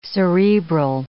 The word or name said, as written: cerebral